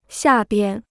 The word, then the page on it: 下边: xià bian